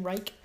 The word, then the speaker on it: Reich: I believe that's how you pronounce his last name, Reich. – Yeah.